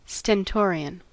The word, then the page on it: stentorian: American English